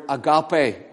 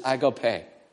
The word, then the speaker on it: agape: So, whenever that's the third one, the fourth word for love is the word agape, or some people pronounce it agape.